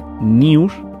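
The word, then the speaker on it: Nius: Una noticia del medio de internet News, no sé si lo conocéis, escribe tal como suena, N y latina US, que es la forma de pronunciar en español la palabra inglesa noticias, News, publicó una noticia cuyo titular decía tal que así el pasado día 16.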